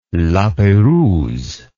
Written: la perouse

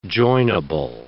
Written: joinable

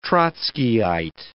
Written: Trotskyite

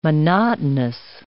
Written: monotonous

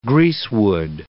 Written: greasewood

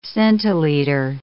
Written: centiliter

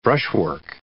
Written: brushwork

